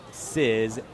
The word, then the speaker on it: Cyyz: TJ Sizz, he spelled it C-Y-Y-Z. Four-letter last name, pronounce it Sizz, S-I-Z-Z.